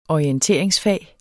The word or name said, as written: orienteringsfag